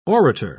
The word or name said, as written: orator